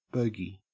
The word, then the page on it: bogie: Australian English